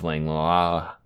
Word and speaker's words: Langlois: My name is Joseph Langlois.